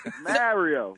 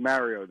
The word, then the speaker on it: Mario: this is Mario. They'd be like, oh, you mean Mario? No, I haven't been mispronouncing my name my entire life.